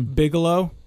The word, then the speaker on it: Bigelow: Can you say his name one more time? – Bigelow. – Yeah. – Like Deuce Bigelow.